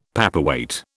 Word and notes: paperweight: British English

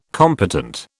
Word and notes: competent: British English

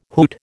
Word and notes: hoot: American English

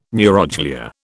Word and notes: neuroglia: British English

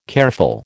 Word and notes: careful: American English

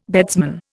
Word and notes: beadsman: Indian

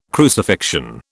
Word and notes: crucifixion: British English

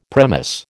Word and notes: premise: American English

